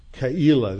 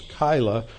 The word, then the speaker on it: Keilah: That's the Hebrew pronunciation. Most people pronounce it the English way as Keilah, which is sort of the German way. When you have an EI in German, you pronounce it like a long I. If it's IE, you pronounce it like a long E. But in Hebrew, it's actually spelled with a Q and then a apostrophe a break, and then Elah. So it's Keilah.